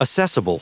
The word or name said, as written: assessable